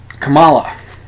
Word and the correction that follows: Kamala: Harris. Kamala Harris. When it's Kamala Harris, the emphasis is on the first syllable, not the second.